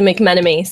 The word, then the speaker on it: McMenemy: Because that one almost seems like it should have extra syllables, like McMenemy. – Yeah, which is exactly what they do, which is why when I graduated with my bachelor's degree, they said McNemony.